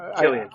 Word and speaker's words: Cillian: Just real quick, does Jimmy remind anybody of Cillian Murphy, if I said it right? – Yes. – Yes. – Yeah. Killian.